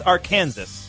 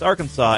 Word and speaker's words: Arkansas: Yeah, it looks like it's Arkansas, but in fact, it's Arkansas.